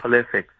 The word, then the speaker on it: Pulisic: And they're going to look different with Pulisic. Hopefully I said his name right. That's his name, right? – Pulisic.